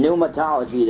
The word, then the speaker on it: pneumatology: In pneumatology, the doctrine of the Holy Spirit. By the way, that's spelled, pneumatology is spelled P-N-E-U-M-A. It's pneuma for spirit. P-N-E-U-M-A, pneuma, and T-O-L-O-G-Y, pneumatology.